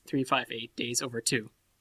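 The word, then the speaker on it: 358/2 Days: It's no, the way they pronounce it is 358 Days Over 2.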